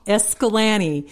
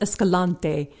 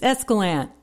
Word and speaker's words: Escalante: A little tiny town that I love in southern Utah called Escalante. It looks like it should be pronounced Escalante, but of course the locals, it's Escalante. – Escalante.